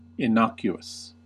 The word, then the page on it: innocuous: American English